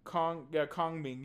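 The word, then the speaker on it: Kongming: Ya boy, it's called Ya Boy Kamoing. I think that's, I'm saying it correctly. – Yeah, boy, come on. – Kong Ming. Ya boy Kong Ming.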